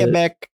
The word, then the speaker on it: Quebec: So in Canada, in Quebec specifically, or Quebec, however you pronounce it. – Quebec. – Is that the right way to pronounce it? – Yes.